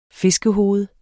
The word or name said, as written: fiskehoved